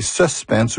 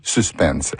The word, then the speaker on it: suspense: Suspense est de genre masculin et peut se prononcer suspense ou suspense.